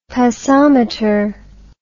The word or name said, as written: passometer